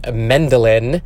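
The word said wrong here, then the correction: Medellín: but it is Medellin.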